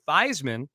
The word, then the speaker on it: Theismann: But Roger Valdeseri, the SID at Notre Dame back in the early 70s, said, no, let's change the pronunciation to Theismann because it rhymes with Heisman, and that'll help your candidacy.